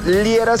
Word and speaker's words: Liera: Mándame un mensajito en cualquiera de las redes sociales, me encuentras por mi nombre, Fernando Liera, se escribe L-I-E-R-A,